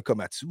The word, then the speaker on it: Komatsu: Peut-être qu'on pourra me corriger sur la prononciation. – Je pense que c'est un Komatsu, si je ne me trompe pas. – Oui, effectivement, comme les pelles mécaniques et le commanditaire de William.